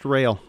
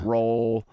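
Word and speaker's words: Roehl: Yeah, we'll just say we are pronounced rail. – You pronounce rail. – Just like railroad. We don't spell it that way. It's R-O-E-H-L.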